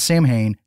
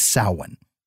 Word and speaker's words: Samhain: It's not Samhain. It's never been Samhain. It's Samhain. Moving on. Loomis says, so is this not the same Samhain then that Glenn Danzig used for a band name? – No.